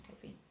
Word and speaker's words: Covey: I think he pronounces it Covey. – Oh, okay. – Well, he doesn't anymore. He passed away. – No, it's for anyone that doesn't know it, it's Covey.